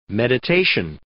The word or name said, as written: meditation